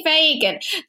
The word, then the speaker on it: Phagan: It's a bit Irish, but they don't spell it the Irish way. They actually spell it P-H, which is a bit sacrilegious. – That's very weird. – P-H-A-G-A-N. Yeah, so last week I did get the name wrong for 50% of the episode, but this week it's definitely Fagan.